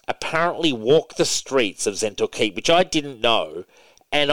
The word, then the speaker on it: Zhentil Keep: Now, turning back to one of my favorite things of all time in the Forgotten Realms, uh, Zhentil, Zhentil Keep is, I'm, I'm pronouncing that correctly, yeah? Zhentil yep, Keep? – Zhentil Keep. – Yep.